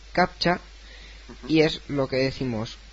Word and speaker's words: captcha: Porque primero hay que decir que captcha, y así haciendo un alarde un poco de la pronunciación inglesa, es Completely Automated Public Turing Test to Tell Computers and Humans Apart.